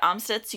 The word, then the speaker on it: Amstutz: And I'm Sandra Amstutz.